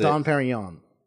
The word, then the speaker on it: Dom Perignon: We have Dom Perignon, or however you pronounce it. – Dom Perignon. – Perignon, yeah."